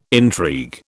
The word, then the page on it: intrigue: British English